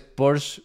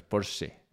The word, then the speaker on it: Porsche: Además, cuando dices Porsche, está mal dicho. Es Porsche. O sea, la E se pronuncia, pero bueno, vale.